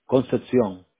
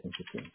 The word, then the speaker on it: Concepcion: Concepcion. – Concepcion? – Sí, Concepcion. – C C-O-N-C-E-P as in Paul, C-I-O-N, right? – That's Concepcion?